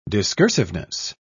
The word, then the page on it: discursiveness: British English